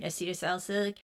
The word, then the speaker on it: acetylsalicylic: Acetylsalicylic acid, if I've said that properly.